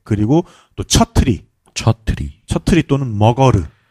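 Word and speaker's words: Magar: 네, 그래서 마가르라고 써놓고 머거르라고 읽어야 네팔식 발음입니다.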